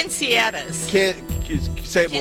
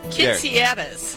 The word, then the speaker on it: quinceañeras: How do you say it? – Quinceañeras. – One more time. – Quinceañeras. – That's how I've been told to say it.